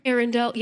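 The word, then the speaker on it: Eärendil: And what is the, the light of Arendil? Did I say that right? – Arendel, yeah.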